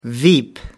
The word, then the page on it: veep: American English